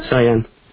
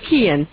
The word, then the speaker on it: Kian: Very few Americans can actually pronounce this without pronouncing it Cyan. – We're going to spread the word. Kian.